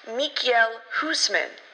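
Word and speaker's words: Michiel Huisman: Michael Huntsman. – Demo, you are so bad with names. It's pronounced Mikael Huseman.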